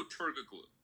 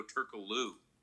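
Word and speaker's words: Türkoğlu: There's no G. G is silent. – There's a G in there, so that's why I say Turkoglu.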